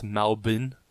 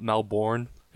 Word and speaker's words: Melbourne: Can I just say, for all you American listeners, it's pronounced Melbourne, not Melbourne.